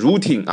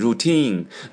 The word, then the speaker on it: routine: 在最最外面的那条路上啊，他在叫，怎么叫？As a routine， routine，注意发音不是 routine 啊，是重音在后面，routine， routine，叫日常工作或者例行公事啊，例行公事就是这个家伙呢，他每天都在叫啊，反正他今天照例例行公事，在最外面的那条路上他在叫，那你说这个安。